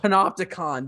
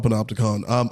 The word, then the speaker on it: Panopticon: Panopticon. That's right. I was like, every time I go to read it, I forget how to say it. – Yeah, it's called Panopticon.